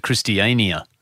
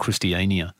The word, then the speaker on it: Christiania: Now, by the way, we learned when we were there, they pronounce it Christiania. Yeah, it is called Christiania.